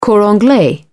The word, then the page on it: cor anglais: British English